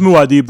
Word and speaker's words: Muad'Dib: Yeah, I'm the least on It's pronounced Muad'Dib, though.